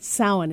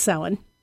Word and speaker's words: Samhain: It's called Samhain. It's spelled S-A-M-H-A-I-N. It's the Celtic way of saying, which the original name for it, All Hallowed Evening, which is what became Halloween. – Right. – But it's Samhain. Some people say Samhain or Samhain. I've heard it pronounced lots of different ways.